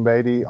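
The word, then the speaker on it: Batey: You can find me under my name, Dwayne Beatty, on Facebook, Dwayne Beatty Kayak Fishing, or my personal account.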